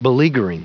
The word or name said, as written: beleaguering